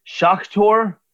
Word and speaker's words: Shakhtar: Shakhtar? No, there's no L. Shakhtar, Soligorsk.